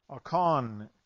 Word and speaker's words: Achan: Achan is the way you would say his name, the one who stole from Jericho.